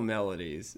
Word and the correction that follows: maladies: Maladies. Magical maladies. Yeah.